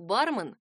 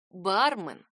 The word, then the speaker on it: бармен: Да, слово «бармен» созвучно именно слову «бакенбарды», а не слову «обмен», например. Ударение в этом заимствованном из английского существительном падает на первый слог «бармен». Большой орфоэпический словарь русского языка отмечает вариант «бармен» как неправильный.